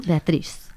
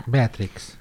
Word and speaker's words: Beatrix: Beatrix, es que es como... A lo mejor no se pronuncia así, ¿no? Beatriz. – Beatrix.